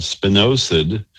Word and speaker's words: spinosad: It's called spinosad. Just spelled like it sounds, like spin, osid.